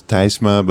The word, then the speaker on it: Tysma: It's T-Y-S-M-A. So you would pronounce it in Dutch, you would pronounce Tysma, but in English it's usually Tysma.